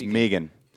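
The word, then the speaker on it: Meeghan: And isn't it Meacham or is it Megan? – It's Megan. – Is it? Was it spelled with a C-H? – It's M-E-E-G-H-A-N. – Oh, G-H. That's what I'm confused.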